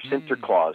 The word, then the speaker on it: Sinterklaas: Now, the Dutch pronunciation of Saint Nicholas is Sant Niklaus or Sinterklaas, mm.